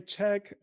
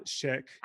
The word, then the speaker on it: Chęć: Can you say it for us, please? – Chęć. Some people say Czech and they also say Jan Chęć.